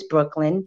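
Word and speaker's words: Breuchkelen: So I'm currently on Instagram at Brooklyn, but it's not spelled the original way. It's spelled the Dutch way, but with a little C included. So it's B-R-E-U-C-H-K-E-L-E-N, which is Brooklyn.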